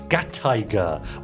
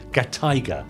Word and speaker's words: Gataiga: Uh, put gatai together with tiger, and you get gataiga, with the emphasis on the tai, i.e., gataiga. Gataiga. That's it.